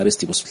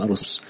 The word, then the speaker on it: أريستيبوس: يعني هو اسمه أريستيبوس لكن يقولون أرستيفوس.